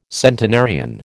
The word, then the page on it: centenarian: American English